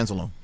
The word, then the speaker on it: Anzalone: And Anzalone, am I pronouncing it properly? – Yeah, Anzalone.